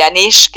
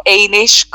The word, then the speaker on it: Anish: y Anish o Einish, como se pronuncia en inglés también.